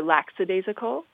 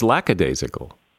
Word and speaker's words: lackadaisical: For example, is it lackadaisical or laxadaisical? – What is it? It's lackadaisical.